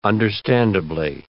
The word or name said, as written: understandably